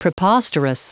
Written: preposterous

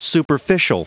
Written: superficial

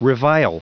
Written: revile

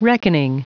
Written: reckoning

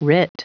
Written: writ

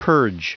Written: purge

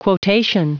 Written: quotation

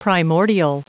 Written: primordial

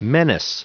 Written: menace

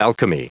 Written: alchemy

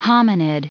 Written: hominid

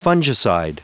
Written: fungicide

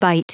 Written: byte

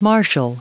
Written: martial

